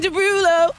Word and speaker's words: Derulo: Jason Derulo.